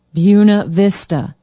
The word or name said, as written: buena vista